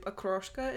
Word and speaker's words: Okroshka: Okroshka is how it's pronounced.